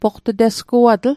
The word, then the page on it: poqt'tesguatl: Mi'gmaq